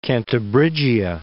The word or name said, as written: Cantabrigia